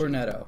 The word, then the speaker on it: Cornetto: Cornello? – It's Cornetto Trilogy. – Cornetto.